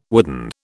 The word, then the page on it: wooden: British English